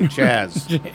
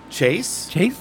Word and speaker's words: Chaz: they'll be able to pronounce it perfectly, Chaz. But if you get an American, someone who speaks English for their lives, they'll look at it and go, Chase?